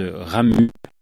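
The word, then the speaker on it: Ramuz: C'est l'auteur se ramue. Avec un Z à la fin, mais les Suisses ne prononcent pas le Z.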